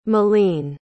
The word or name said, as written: méline